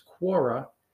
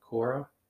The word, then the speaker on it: Quora: And if you're not from Northern New Jersey, perhaps you would pronounce it differently. Quora. I say it like Quora because I'm from Northern New Jersey and we have an accent a little bit like New Yorkers where our R's are funny.